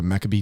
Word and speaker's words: Maccabee: Tyra is a member of Golden Circle, and her and Kyle lead the, do you guys call it the Maccabee team? – Yeah. – And did I pronounce the name right? – Yes, you did.